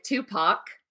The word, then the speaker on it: Tupac: And they end up talking about, let's hope I say his name right, Tupac. Is that right? Kevin's the one who corrected me before. – Yes.